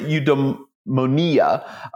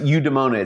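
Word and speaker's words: eudaemonia: Some people pronounce it eudaimonia. Other people pronounce it eudaimonia. It's E-U-D-A-E-M-O-N-I-A.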